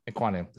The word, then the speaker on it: Ekwonu: Ekwonu. – Yep, Ekwonu.